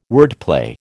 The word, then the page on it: wordplay: American English